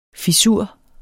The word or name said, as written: fissur